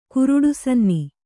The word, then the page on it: ಕುರುಡುಸನ್ನಿ: kuruḍu sanni